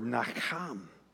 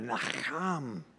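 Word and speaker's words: nacham (נחם): Again, the Hebrew word nacham. You got to get that deep guttural in there, nacham. To say it right, I just like doing that.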